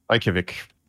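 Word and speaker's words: Reykjavík: The Valorant Champions Tour 2021, the Masters at, how do you say this? Is it Reykjavik? – Reykjavik.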